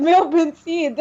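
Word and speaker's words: Millepensee: Is it Millipinsy or Millipenis? I don't understand. – I'm not going to attempt. – It's Millipinsy. – It's Millipinsy.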